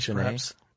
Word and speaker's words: Chesnais: Yeah, a former employee named Fred, I don't know how to pronounce that, C-H-E-S-N-A-I-S. – I believe is one Chenet. Of the French fellows from Infogrames, Chenet, perhaps.